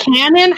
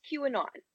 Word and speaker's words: QAnon: Is it canon? How do you pronounce this word? – It's QAnon.